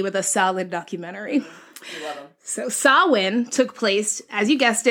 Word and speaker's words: Samhain: So the foundation of Halloween can be traced back over 2,000 years to a Celtic pagan holiday called Samhain. So it's spelled Samhain,